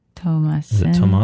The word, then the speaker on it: Thomasin: Thomasin.